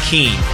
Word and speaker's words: keen: Hope all is peachy king. Is it king? Like, you know, K-I-N-G? – Peachy keen, I think. – What is it? How do you spell it? – I think it's K-E-E-N.